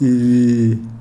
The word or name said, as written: ibii